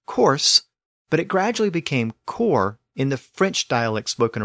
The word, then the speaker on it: corps: We can also see this distinction in words like corpse, C-O-R-P-S-E, and core, C-O-R-P-S. Both words are still spelled with an S, but the pronunciation of the S was lost in core.